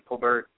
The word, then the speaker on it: Colbert: She does actually pronounce her name Colbert with a T pronounced.